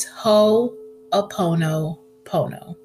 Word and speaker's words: Ho'oponopono: Besides it being a mouthful to say, when I first learned about it, I called it Ho'oponopinopi, Ho'oponiponi. It was hard. I had to YouTube the pronunciation of this word, but I finally got it together, y'all. It's Ho'oponopono.